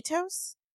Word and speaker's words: Aetos: Also, I heard you call his dad, what, how do you say their last name? – Etos. – Okay, that confused me because I was like, who the hell is Etos? I was saying Atos. – Etos, I mean. – A-E-T-O-S.